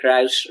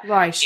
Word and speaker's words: Ruysch: I'm going to mispronounce this, but just correct me, Frederick Ruysch. – Is that? I think that's fairly okay. I think, yeah, Frederick Ruysch. – Ruysch.